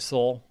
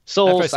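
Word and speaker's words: Sowell: Pass on Bradley Saul. His time is done. – Technically, isn't it Soul? – Soul. Well, I said Sal. Yeah. I was like, that's not right.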